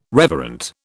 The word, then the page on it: reverent: British English